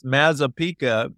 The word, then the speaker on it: Mazzapica: You pronounced it perfect. It's Mazzapica. But, you know, and interestingly enough, you pronounce it exactly the way you spell it.